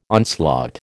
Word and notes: onslaught: American English

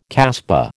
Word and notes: casbah: American English

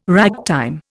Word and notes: ragtime: Indian

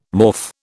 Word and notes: morph: British English

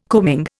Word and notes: coaming: Indian

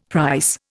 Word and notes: price: Indian

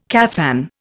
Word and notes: caftan: Indian

